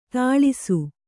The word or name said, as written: ಥಳಿಸು